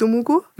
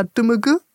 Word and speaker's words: Dumugu: Also die französische Aussprache ist jetzt Dumugu, aber jetzt ist es ein bisschen linguistisch. – Ja. – Die haben so Schwa-Laute, also selber sagen sie eigentlich eher Dumugu, etwas in die Richtung.